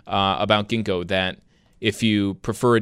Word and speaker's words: Ginkgo: Like the tree. Think ginkgo tree or ginkgo leaves.